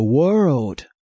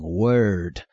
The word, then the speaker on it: world: For God so loved the world. The world, 这个 world 就是世界，注意我们华人对这个 world 发音不太准确，很多人都念成 word，可是呢 word 是字， world 是世界，是不一样的，所以我们要常加练习。